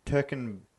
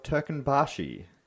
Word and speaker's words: Turkmenbashi: Or the following year, Niyazov declared himself Turkmenbashi. That one's a hard one because it's got a couple of umlauts in there and a few other things, but I think that's how it's pronounced.